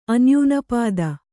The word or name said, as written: ಅನ್ಯೂನಪಾದ